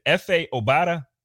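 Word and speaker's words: Efe Obada: Is it F.A. Obada? – Yeah. – Okay, well, I said it right.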